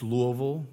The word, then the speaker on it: Louisville: When our son was one or two, Morgan drove downtown in Louisville, Kentucky. It's Louisville, right? It's Louisville, not Louisville. Right? I got one. Phil Nelson knows how to say it.